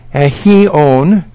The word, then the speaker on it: aion: It is the word aion, pronounced aheon, and this is in Strong's dictionary.